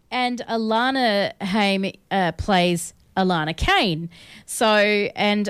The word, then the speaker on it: Haim: Is it Haim or Haim? – Haim. It is Haim.